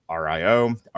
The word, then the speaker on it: RIO: Maverick and Merlin, Kurgar's former RIO, are assigned as backup. Pronounced Rio, actually.